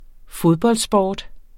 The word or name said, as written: fodboldsport